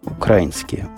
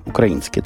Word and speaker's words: украинские: Про украинские биржи ничего сказать украинские. Укра... Не, украинские, точно Украина, значит украинские. Я все время не туда ставлю ударение, а называю так, как у нас в городе Жданове Мариуполе это называлось.